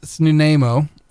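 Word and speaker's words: Snuneymuxw: The Nanaimo, pronounced Snunaimo, or with that XW at the end if you can get your throat to do it. It's kind of a hard one.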